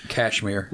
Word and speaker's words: Cashmere: Is it spelled like it's Cashmere. – Okay, so like the material, not the Led Zeppelin song?